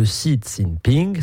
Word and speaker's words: Xi Jinping: Vous prenez pour point de départ la visite, c'était la semaine dernière, de Xi Jinping, cette fois c'est bien prononcé, à Washington.